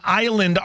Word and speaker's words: island: Isle and island aren't actually related words at all. Island only picks up its silent S because people wrongly assumed it came from the word aisle. – Really? – Isle is an I-S-L-E, not aisle, A-I-S-L-E.